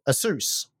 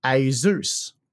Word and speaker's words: Asus: The only problem is you've said Asus, because that's how I've always said Asus. And I thought that was correct. And they said, you're mispronouncing the name. I'm like, okay, how do I pronounce the name? And then they sent me something that was Asus, and they said that's how you say it.